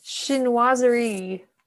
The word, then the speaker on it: Chinoiserie: Chinoiserie. – Okay. Chinoiserie and bamboo.